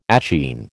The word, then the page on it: achene: American English